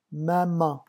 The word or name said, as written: maman